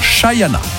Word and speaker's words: Shania: Shania Twain, avec l'accent s'il vous plaît, ça s'écrit Shania, mais il paraît, j'ai appris ça il y a peu de temps, qu'il faut dire Shayana.